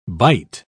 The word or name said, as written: byte